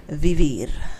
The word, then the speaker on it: vivir: So live me, live in Spanish is vivir. – Vivir. – I see you trying to roll your R.